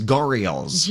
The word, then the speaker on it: gharials: All right, so the Fort Worth Zoo announcing that 8 gharials. Am I saying that right? – I don't know. – I think it is gharials. – G-H-A-R-I-A-L.